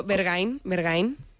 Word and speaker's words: Berghain: Eso, Berghain.